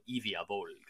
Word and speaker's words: Ivya Volga: the um, for the the Bone Reapers and um, Ivya Volga for um, I think you have to say it like this, Ivya Volga, like that. It's kind of you know sort of Dracula type thing uh, for for the Soul Blight um, the Soul Blight Grave Lords.